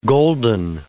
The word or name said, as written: golden